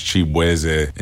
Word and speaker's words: Chibweze: I'm Nigerian-American, so my last name is actually pronounced Chibweze. And just growing up in Virginia, like, everybody just did not know how to enunciate my name. So they was just like, Shabuzi.